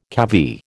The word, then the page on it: cavy: American English